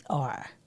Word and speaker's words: Usher: Old people, once you hit a certain age, which we apparently have not hit because we still say it correctly, they say Usher. – Usher. – There's like an extra R.